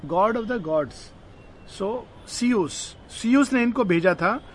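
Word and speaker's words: Zeus: Z U S नाम है लेकिन उसको प्रोनाउंस किया जाता है सीयूस सीयूस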